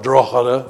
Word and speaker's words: Drogheda: Drogheda, or Drogheda, if you want to pronounce it right.